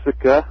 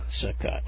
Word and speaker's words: Sitka: You call it the Sitka? How do you say that word? – The Sitka. – Sitka, okay.